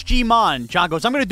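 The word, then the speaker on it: Ji-man: And I'm pretty sure pronounced G-Mon, not G-Man.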